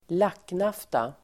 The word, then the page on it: lacknafta: [²l'ak:naf:ta]